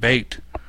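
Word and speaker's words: B8: It's spelled like the number 8 with a capital B in front, Bait. And the name of the song is Invisible City.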